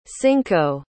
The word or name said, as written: cinco